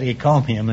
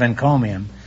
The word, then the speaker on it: encomium: For some curious reason, I said ecomium instead of encomium.